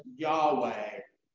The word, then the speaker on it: Yahweh: The word Lord in the Hebrew language is written YHWH. And in the language of English, we pronounce that as Yahweh or Jehovah.